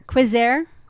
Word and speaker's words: Queisser: Q-U-E-I-S-S-E-R. Quiser? – Quiser. You have to say it like that. – No, because that would imply there's an I-E-R at the end.